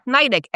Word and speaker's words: Nydick: And when I spell it, people laugh because I say, it's Beth Nydick, NYDick.